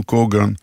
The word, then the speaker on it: קוגן: ברוסית, במקום האות ה' מבטאים ג', וכך במקום כהן, עולי רוסיה מבטאים קוגן, במקום כהן, הם מבטאים קגן.